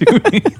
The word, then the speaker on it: Dodie: Is it Dodie Smith? – I thought it was Dodie. – It's Dodie. Well played. It should be Dodie.